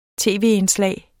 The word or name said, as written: tv-indslag